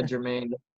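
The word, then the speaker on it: Germaine: Am I saying that right? Jermaine, yeah. It's just spelled with a G and it's throwing me off. – Yeah, Jermaine.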